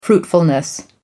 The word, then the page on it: fruitfulness: American English